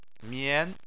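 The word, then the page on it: mian: tone 2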